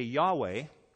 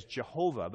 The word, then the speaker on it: Yahweh: And so we pronounce it today Yahweh. In the past, they pronounced YHWH as Jehovah, but that's really not the way it should be pronounced.